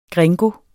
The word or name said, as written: gringo